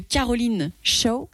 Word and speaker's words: Caroline Shaw: Mais j'ai eu un gros coup de cœur pour le son que tu nous as choisi de Caroline Shaw.